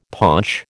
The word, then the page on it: paunch: American English